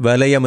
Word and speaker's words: வலையம்: கலையம் என்பதும் வலையம் என்பதும் சரியானது. கானா லானா வானா லானா. கலையம் வலையம், அது உச்சரிக்கிற போது சில பேர் வலையம் என்றது போல் உச்சரிப்பாக, அது வலையம் என்று வராது, அது வலையம் என்று தான் வரும்.